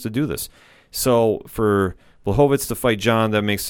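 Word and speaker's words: Błachowicz: I mean, Blachowicz is going to go fight John, or actually it's Blachowicz. I always mispronounce his name.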